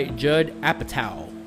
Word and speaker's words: Apatow: This was directed by Judd Apatow. Yes, that's how you pronounce it.